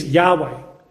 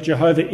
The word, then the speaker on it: Jehovah: Now, in recent times, scholars have proposed that the correct pronunciation of this name is Yahweh, which is constructed using the vowels of Adonai in the tetragrammaton. However, there is greater evidence to show that Jehovah is the correct pronunciation based on the Masoretic Hebrew text, which we believe is the preserved Hebrew text.